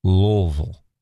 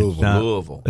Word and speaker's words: Louisville: It is Louisville. All right? – Louisville. – It's not Louisville. It's not Louisville. It is Louisville. That's how you say it.